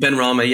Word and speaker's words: Benrahma: Benrahma, is that correct? – Benrahma, yeah.